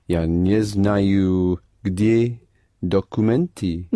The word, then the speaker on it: документы: Документы? – Да. Only the first or is not accented, so pronounce it the Russian way. – Документы. – Pronounce it more Russian, as in da.